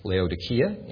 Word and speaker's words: Laodicea: The name Laodicea, I think, is pronounced Laodikia in Greek.